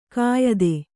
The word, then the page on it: ಕೆಯ್ದು: Kannada